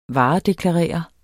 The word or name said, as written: varedeklarere